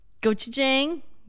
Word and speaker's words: gochujang: And then that got layered with gochujang.